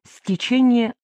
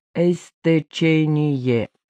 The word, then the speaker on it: стечение: «Эстечение». В группе большинство испаноговорящих студентов из Латинской Америки. Им сложно произносить русские слова, начинающиеся с двух согласных. Невольно подставляют в начале «э», говорят «э спасибо», «э здравствуйте».